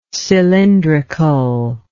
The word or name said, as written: cylindrically